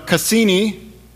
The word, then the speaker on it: Cassini: You know, if you were paying attention to the news really at all this last week, the Cassini, am I saying that right? Yes, the